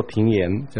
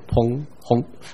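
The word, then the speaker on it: 砰訇: 第三节那两个字不要把它读错了，其中的水虽砰轰翻腾，不要读作平言，就但是读到砰轰。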